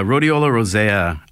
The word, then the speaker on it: Rhodiola rosea: Rhodiola rosea. – Yeah, Rhodiola rosea.